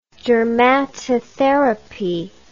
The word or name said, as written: dermatotherapy